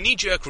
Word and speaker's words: knee-jerk: and I will put the emphasis on the first syllable just to be nice, but your knee-jerk reaction, as is mine, is to say that this person is wrong and weirdly, unhealthily wrong for believing what they believe.